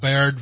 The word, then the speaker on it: Baird: I believe it's pronounced Baird. It's B-A-I-R-D.